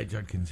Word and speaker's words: Judkins: Yeah, Judkins, yeah.